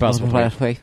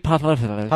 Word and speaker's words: Postlethwaite: The late Pete Postlethwaite. – Who died recently in January, right? – Yeah, just like this year. Pete Postlethwaite.